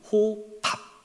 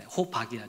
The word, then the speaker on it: 호밥: 발음을 잘해야 되는. 발음을 잘해야 되네요. 호밥. 네, 호박이 아니라 호밥. 호밥이라는 사람 여러분 기억하세요?